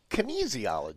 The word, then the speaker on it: kinesiology: Did you call it asphysiology? – Kinesiology. – Kinesiology. – Yeah. Kinesiology. – Yeah. – Kinesiology. Easy as knees.